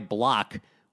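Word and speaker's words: Blach: He spotted the Orioles a 6-0 lead, matter of fact, but then the Orioles completely imploded, starting with their starter, Ty Block, which is actually spelled like somebody vomiting, which is appropriate. It's B-L-A-C-H, like black.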